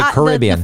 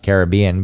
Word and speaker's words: Caribbean: But say Caribbean's actually Caribbean. It should be pronounced Caribbean because the native people of the land, which I wish I could, were Caribs, I think.